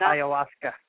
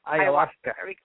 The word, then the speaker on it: ayahuasca: ayahuasca? – Ayahuasca. Yeah, ayahuasca.